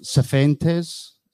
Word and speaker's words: Cifuentes: We're linked and rumoured with, how do you say it? Cifuentes. That's how you say it.